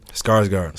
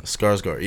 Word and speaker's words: Skarsgård: But, number one is Stellan Skarsgård. – Skarsgård, yeah.